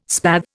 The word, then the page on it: spathe: Indian